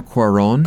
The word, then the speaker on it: Cuarón: Alfonso Cuarón.